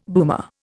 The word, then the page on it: boomer: Indian